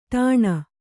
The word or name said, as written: ಟನ್